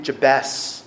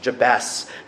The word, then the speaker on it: Jabez: His mother had named him Jabez, which is how it's pronounced in Hebrew. We'll go for the Hebrew pronunciation. Jabez, saying, "I gave birth to him in pain."